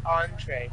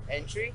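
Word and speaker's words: entrée: So, how do you pronounce that again? – Entree. – Entree? – Yes. – All right. So, like, when I asked you the first time, when I said entry, you didn't know what it was at all, right? – Entry? – Entry, yeah. – I figured it was entree.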